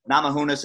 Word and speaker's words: Namajunas: If you guys, you know, pay attention to that, that's Andrade and how do you say her last name? Rose Namajunas? – Namajunas and Andraj.